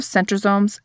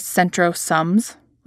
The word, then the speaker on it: centrosomes: Side note, I think of centrosomes as centrosums, like S-U-M-S, like they are just some organelle hanging out in the cytoplasm. I put the emphasis on pronouncing it incorrectly so that I can remember what it is and what it does.